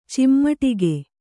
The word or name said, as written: ಚಿಮುಟಿಗೆ